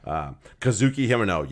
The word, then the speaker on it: Kazuki Himeno: So Kazuki Himeno, yes, I said it right.